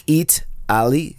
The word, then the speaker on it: Eataly: They have this place called Italy, but spelled like EATALY, you know, like Eat Ali, like Italy, but with, you know, food.